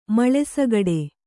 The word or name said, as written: ಮಳೆಸಗಡೆ